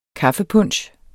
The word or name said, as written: kaffepunch